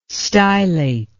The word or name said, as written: stylate